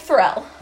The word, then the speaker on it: Ferrell: No, and I didn't either, and I'm such a huge Will Ferrell fan, but Ferrell, whatever. – I think Ferrell. – It's the wrong emphasis on the wrong syllable.